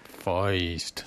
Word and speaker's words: Feist: How do you pronounce it? Feist or Feist? I always get into arguments over this one. – Feist. – Feist. Okay, so it's with the weaker than. So you can actually pronounce it the German way, right? – I guess so.